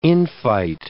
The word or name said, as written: infight